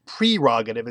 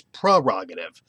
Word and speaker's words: prerogative: The one thing I want to make a point of it's not the prefix pre, it's not pronounced prerogative, it's prerogative. It's what's called a schwa sound after the E. It's like a uh prerogative. I still can't say it.